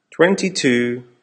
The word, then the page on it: twenty two: American English